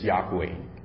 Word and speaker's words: Yahweh: And some Bible translators, they actually combine the vowels of Adonai to the tetragrammaton, the consonants, and came up with the pronunciation Jehovah, as in the case of Jehovah Witnesses. However, other Bible scholars, they believe the actual pronunciation of God's name is Yahweh.